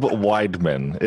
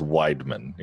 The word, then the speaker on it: Weidman: So that is Caleb Weidman. It is an E-I, Weidman. But trust me, I got it wrong all throughout school, but it is Weidman.